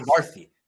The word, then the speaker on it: Chakravarthi: Chakravarthi.